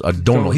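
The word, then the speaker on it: Domhnall: This is an actor by the name of Donal Gleeson. It's D O M Gleason, N-H-A-L-L, but I believe it's pronounced Donald.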